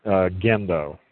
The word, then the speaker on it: Gendo: No, it's a hard G. Gendo.